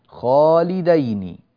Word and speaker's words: خالدين: خالدين خالدين كما ترونها على الشاشة، هذه الكلمة تنطق بالتثنية، نحن معتادين على أن نرى كلمة خالدين في القرآن الكريم كلها بالجمع، خالدين خالدين خالدين، أما هنا فخالدين خالدين، يعني الدال هنا كما ترونها بوضوح أمامكم على الشاشة دال مفتوحة، دال مفتوحة، خالدين دين بارك الله فيكم.